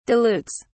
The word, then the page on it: dilutes: British English